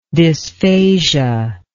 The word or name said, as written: dysphasia